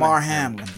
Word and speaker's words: Damar Hamlin: is that how you spe- uh, pronounce yeah, it? Latin? Damar Hamlin. – Yes.